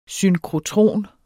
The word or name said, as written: synkrotron